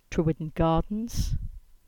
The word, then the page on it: Trewidden Gardens: English